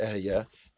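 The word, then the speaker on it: Ehyeh: Hakadosh Baruch Hu says, "Ekia Asher Ekia." I'm mispronouncing it. It's Ehya.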